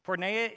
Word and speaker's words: porneia: Porneia is an ancient Greek word found in the biblical manuscripts. It may be pronounced porneia. Can you say that with me?